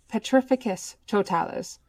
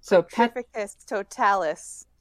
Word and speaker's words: Petrificus Totalis: "So it's pronounced Petrificus Totalis. – Petrificus Totalis."